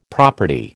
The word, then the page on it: property: American English